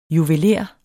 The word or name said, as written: juveler